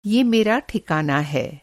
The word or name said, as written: ठिकाना